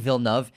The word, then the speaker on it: Villeneuve: It won Best Cinematography for The GOAT, Roger Deakins, as well as Best Visual Effects, obviously directed by Denis Villeneuve. And yes, that's how you pronounce his name.